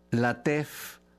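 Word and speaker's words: Lateef: Latef, ya supongo yo que Latif, pero con doble E, o sea, Latef, Latef.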